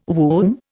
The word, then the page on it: womb: Indian